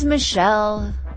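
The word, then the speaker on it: Michele: This is Michelle, spelled with one L. My name is Michelle, spelled with one L. I am Michelle.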